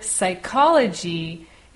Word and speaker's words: psychology: But when we write the word psychology, it actually starts with the letter P, which is not pronounced.